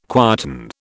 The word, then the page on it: quieten: British English